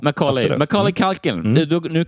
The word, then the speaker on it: Macaulay: Det kan man tänka sig, man står M-A-C-O-L-E-Y typ, Macaulay. – M-A-C-A-U-L.